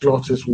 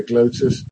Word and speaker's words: glottis: So, for example, glottis in English is spelt with two T's and is pronounced glottis. We wouldn't say glotis because there are two T's.